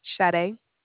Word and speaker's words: Sade: Sade. – I'm sorry. Would you please spell that? – Yes. It's spelled S-A-D-E. – S-A-D-E.